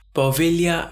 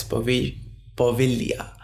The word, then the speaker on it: Poveglia: It's spelled as P-O-V-E-G-L-I-A, but according to the little research I did on the pronunciation part, G is not really pronounced properly and apparently V is a V, not a B.